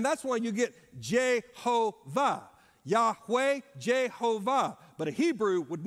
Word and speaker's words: Yahweh: And so our best way of pronouncing it is Yahweh or Yahweh.